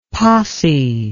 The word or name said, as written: possy